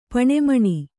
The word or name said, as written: ಪೆಣ್ಮಣಿ